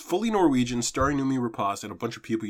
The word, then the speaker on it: Rapace: I always say Numi Rapace. Thank you, Alex. It's Rapace. – Rapace. – I looked up her pronouncing it. It's Noomi Rapace.